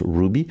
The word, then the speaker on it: RWBY: Ça s'écrit R-W-B-Y, mais ça se prononce Ruby,